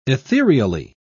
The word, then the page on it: ethereally: British English